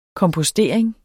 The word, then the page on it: kompostering: kɔmpʌˈsdeˀeŋ; kʌm-; -po-